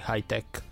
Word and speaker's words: Hightech: Non lo so, tu dici spesso Hightech, credo che l'accento sia sulla te, quindi Hightech, però non so cosa ti stava contestando.